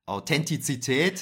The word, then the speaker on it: Authentizität: Authentifiz äh, Authentifizität. Nee, jetzt habe ich falsch ausgesprochen, ne? Warte mal. Authentizität.